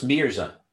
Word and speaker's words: Mirza: So it's Mirza, M-I-R-Z-A.